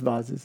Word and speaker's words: vases: The most it's pronounced vases. – Vases. I'm so sorry. Let me say that again.